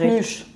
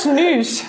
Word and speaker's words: Schnüsch: Äh, vielleicht könnt ihr einmal für die Hörerinnen und und Hörer erklären, was ist Schnü überhaupt für ein Gericht? – Schnüsch. – Schnüsch. – Ja. Jetzt habe ich sogar noch falsch ausgesprochen. – Schnüsch.